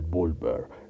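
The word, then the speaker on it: Wulver: El Gulber no, ha de ser B, ¿verdad? Porque se pronuncia como B, la W en algunos lados. El Bulber.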